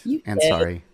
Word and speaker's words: Ansari: And Layla, did I say your name right? – Ansari. You did.